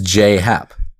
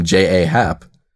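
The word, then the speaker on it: J. A. Happ: he pronounces his name as J.A.Y., so J. – So it is J.Happ.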